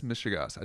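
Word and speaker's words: mishegas: It's this mishigas. I tell you what. Did I say it right? Yeah, you did.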